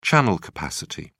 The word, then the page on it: channel capacity: British English